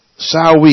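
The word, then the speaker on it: Samhain: A Sawi is spelled S-A-M-H-I-H-A-I-N, and say it again, S-A-M, Sam, H-A-I-N. And you're saying, Sawi is spelt that way? I say, yes, it's Celtic.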